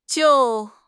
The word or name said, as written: jiu4